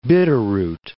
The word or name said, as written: Bitterroot Range